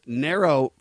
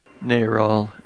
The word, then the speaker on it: NARAL: David Capellian's revealing interview with Nero founders. How you say it? Nero or Nero? – Narall. N-A-R-A-L.